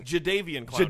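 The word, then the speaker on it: Jadeveon Clowney: Javadon Clowney. I just was saying Jadavian Clowney.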